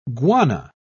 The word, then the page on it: guana: British English